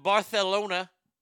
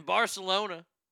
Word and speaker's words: Barcelona: In Barcelona, the C is pronounced TH there.